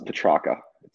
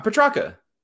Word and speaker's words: Petrarca: So it's Petrarca. – You had it. You got it. – Okay, I had it. Pronounce it. Pronounce it. I want to hear you. – Petrarca. – Yeah, it's perfect.